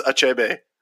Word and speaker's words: Achebe: And we were unsure of the pronunciation, but we both figured it was the same as author Chinua Achebe. The, like, Trebek dropped the pronunciation on us, so I just texted you, context-free, Trebek says it's Achebe.